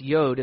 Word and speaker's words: jot: It's actually pronounced yod. It's the smallest letter in the Hebrew alphabet.